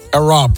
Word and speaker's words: Arab: Arap nasıl okunuyor İngilizce? İngiliz aksanıyla okuyamadım onu ya. Central Bank of the United Arab Emirates.